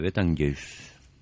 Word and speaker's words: Betelgeuse: Sí, de l'estrella Betgus. – Betelgeuse. – Betelgeuse.